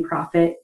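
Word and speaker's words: Proffit: And I was actually born with the last name Profit. It's spelled like making money, but with two F's.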